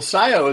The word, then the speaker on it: Cyio: Well, Cyio is actually a spin. It's spelled C-Y-I-O. It's a spin from the Latin term S-C-I-O, which means to know.